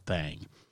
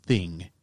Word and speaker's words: Thang: but it's called the Alabama White Thang. T-H-A-N-G, because you know that you got to pronounce it with a southern drawl. So it's not the thing, it's thang.